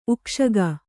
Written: ಉಕ್ಕುಸೊಕ್ಕು

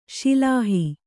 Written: ಶಿಲಾಹಿ

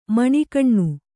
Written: ಮಣಿಕಣ್ಣು

